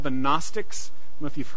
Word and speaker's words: Gnostics: So they were called the Gnostics. So you don't always pronounce the G.